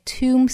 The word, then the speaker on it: tomb: 我们再来看美音。同样呢，清明节 Tomb Sweeping Day，要注意 tomb， tomb，末尾的 b 不发音，sweeping，